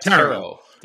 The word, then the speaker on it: Tarot: I just watched Tarot, whatever how you say it. How do you say it? – Tarot.